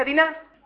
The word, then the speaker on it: Dina: En hebreo, Dina se pronuncia Diná, o sea que no hay mucha diferencia con el castellano. Es un acento en la vocal A.